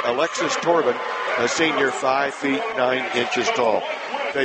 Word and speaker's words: Thorbahn: Get this. Turban. It's spelled T-H-O-R-B-A-H-N. – Her name's Alexis today for me.